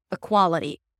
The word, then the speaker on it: equality: When you say the word equal, the primary stress falls on the E, and that's a high E. Equal. But the word equality is a little different, where the stress shifts to qua, and the tense E becomes I.